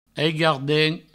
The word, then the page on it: aigardent: aigo-ardent